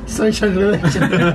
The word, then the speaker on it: Shawshank Redemption: ¿Y cómo se dice entonces? Shawshank Redemption.